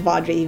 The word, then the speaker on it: Vaudrey: And then uh, my last name is Vaudrey, V as in Victor, A U D R E Y.